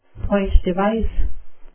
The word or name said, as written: point-device